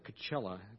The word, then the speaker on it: Coachella: Coachella. Does that rhyme with anything? Coachella, Luella, I don't know. Nutella, thank you.